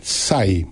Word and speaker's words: Tsai: Y luego TSAI, SAI. Pero hay una T al principio que por supuesto no se pronuncia.